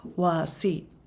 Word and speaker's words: Wasi': Now, Wasi is spelled W-A-S-I apostrophe. And in this dialect, that apostrophe means something. It means kind of it almost sounds like Wasi. It's like a sound. It's not anything we do in our language, but it's kind of beautiful.